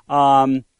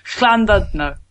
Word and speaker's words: Llandudno: Llandudno, I would have said. – Llandudno, no. Llandudno. – Llandudno. – Close.